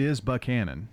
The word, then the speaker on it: Buchanan: And it's mispronounced quite frequently. – Right. – It is Buchanan.